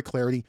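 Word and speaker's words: Clardy: You can follow me on Twitter at Troy Clarity. Last name is spelled C-L-A-R-D-Y. C-L-A-R-D-Y at Troy Clarity on Twitter.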